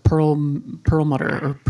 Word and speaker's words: Perlmutter: And in that book, I think it's his name is pronounced Perlmutter.